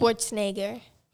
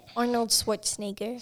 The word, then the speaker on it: Schwarzenegger: Schwarzenegger. – How does she say it? – Arnold Schwarzenegger.